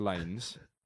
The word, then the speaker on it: Lanez: We know that is pronounced Lanes because it's just replaced an S with a Z. Same phonic. But adding an extra E to the...